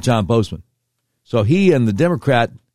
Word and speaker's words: Boozman: You might see his name and say, isn't that pronounced Bo- Boozman? No, it's John Bozeman. He is U.S. Senator from Arkansas, the one that's never on TV, the one you've never heard of if you're outside Arkansas.